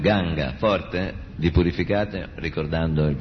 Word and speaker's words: Ganga: In Sanskrit, Ganges is pronounced with the two-syllable word Ganga.